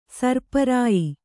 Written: ಸೀರಿಪ್ರಿಯೆ

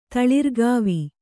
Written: ತಳಿರ್ಗಾವಿ

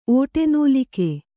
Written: ಓಟೆನೂಲಿಕೆ

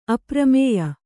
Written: ಅಪರಿಮೇಯ

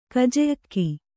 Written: ಕಜೆಅಕ್ಕಿ

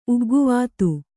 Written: ಉಗ್ಗುವಾತು

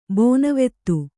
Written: ಬೋನವೆತ್ತು

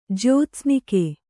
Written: ಜ್ಯೋತ್ಸ್ನಿಕೆ

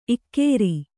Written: ಇಗುರು

